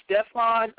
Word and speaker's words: Stefan: My name is Stefan Augustus Lambert. That's spelled S-T-E-F-A-N, Augustus, as in Caesar, and then Lambert.